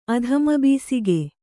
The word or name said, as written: ಅಧಮಬಿಸಿಗೆ